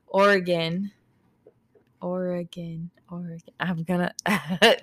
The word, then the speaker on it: Oregon: They stopped at a quiet spot on the road in Springfield, Oregon. – Oregon. – Oregon. – Oregon. Oh. Like, imagine Oregon, like you're playing one like a piano.